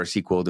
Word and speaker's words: SQL: It'll write a SQL or SQL, depending on who you talk to, how they pronounce it, query.